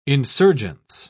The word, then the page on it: insurgence: British English